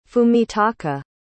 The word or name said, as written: fumitaka